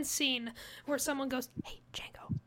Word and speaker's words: Django: Demigio? – It's Django.